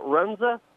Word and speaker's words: Runza: Runza? – Yeah, yeah.